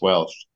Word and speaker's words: Walsh: That's how you would pronounce my name in Ireland. In many parts of Ireland, it would be pronounced Welsh.